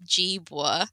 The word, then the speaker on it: Jeevwa: So it's Jeevwa with a, it's like a J instead of a G.